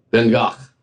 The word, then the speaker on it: Van Gogh: How do you pronounce Van Gogh? – Van Gogh.